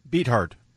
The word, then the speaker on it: Beathard: Beathard. – That's correct. That's an alternative pronunciation.